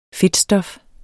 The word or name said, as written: fedtstof